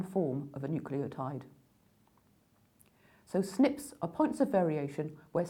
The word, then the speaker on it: SNPs: These are abbreviated to SNPs, which is actually pronounced SNPs.